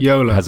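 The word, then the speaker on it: Jolla: The Yola phone. – Yola.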